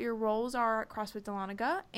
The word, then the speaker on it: Dahlonega: I'm here with two very special guests, Thomas and Robbie from CrossFit Dahlonega. Did I say that right? Did I mess it up? Yes. – Yeah, Dahlonega.